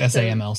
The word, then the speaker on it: SAML: So OAuth is a protocol, and SAML, is that how you say it right, is another? – Correct, SAML, SAML.